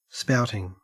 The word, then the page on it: spouting: Australian English